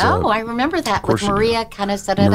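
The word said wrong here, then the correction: Kanakaredes: Maria Kanakaridis, yes. Thank you. That was the first thing I learned is how to pronounce the star's name.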